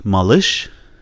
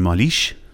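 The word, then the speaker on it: مالش (малыш): مثلا مالش، مالش میشه بچه کوچولو، نینی، قنداقی. اگه بگین مالیش، معنی نمیده. مالیش، مالش، مالش، مالش همین ای وسطشه.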